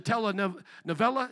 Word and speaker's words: telenovela: If you have ever watched a telenovela, is that how you say it? Telenovela? I did say it right the first time. Thank you.